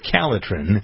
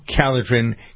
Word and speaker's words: Calotren: I saw the story of somebody who lost 35 pounds with Calitrin. Calitrin is spelled C-A-L-O-T-R-E-N, because there are a lot of people who confuse it with its main ingredient, collagen. Collagen, Calitrin. Calitrin is what you want to remember.